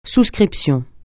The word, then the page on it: souscription: francais